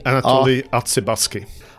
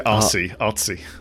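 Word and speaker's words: Artsebarsky: How do you pronounce that? Anatoly Artsibarsky. – Artsibarsky. – Yeah. – Anatoly Artsibarsky. – Don't say Artsy.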